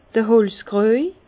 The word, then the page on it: d'r Holzkrüej: Bas-Rhin; Herrlisheim